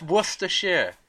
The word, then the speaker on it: Worcestershire: You missed off the last five letters. – Well, okay. Oh, Rochester. Rochester. I don't know how to pronounce that. – It's Worcestershire. – Worcestershire? – Worcestershire. Okay.